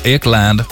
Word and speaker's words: Ekland: Hon föddes Eklund, men artistnamnet blev det engelska uttalet Ekland.